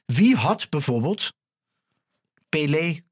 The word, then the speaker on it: Pelé: Maar we hebben het natuurlijk over Edson Arantes do Nascimento, beter bekend als Pelé. – Ja, dat spreek je gewoon helemaal goed uit. – Weet je, je kan over Pelé, je 28 podcasts houden, ben je nog niet uitgepraat.